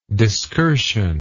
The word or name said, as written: discursion